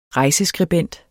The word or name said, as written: rejseskribent